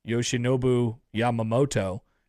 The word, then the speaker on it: Yoshinobu Yamamoto: Yoshinobu Yamamoto has been pitching very, very well.